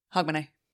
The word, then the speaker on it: Hogmanay: H-O-G. – M-A-N-A-Y. – Okay. Hog, man, A. – Yeah. That's it. – Hogmanay.